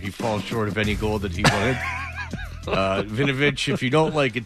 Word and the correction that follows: Winovich: Are we saying it wrong? It's Vinovich? – Winovich. – Even though it's got a W? – Oh, I'm sorry.